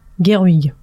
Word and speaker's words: Gerwig: Greta Gerwig en c'est comme ça que ça se prononce.